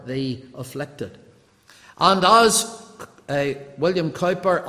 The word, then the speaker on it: Cowper: But one of the most touching aspects of John Newton's life was his tender care for the very famous English poet William Cowper, or Cooper, as some people pronounce it, probably more correctly than the way we pronounce it.